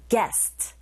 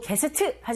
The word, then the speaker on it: guest: Another, 그리고 게스트 하지 마시고 guest, guest. 이 부분에 강세를 꽝 주시고 뒤에 visit 나왔습니다.